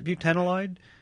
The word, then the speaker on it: butanolide: Well, we'll make you the first ever Science Friday awardee for the winner of the international competition for the search for butanolide. Did I say that right? Is it butanolide? – Yeah, it's in a class of compounds.